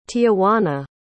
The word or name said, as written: tijuana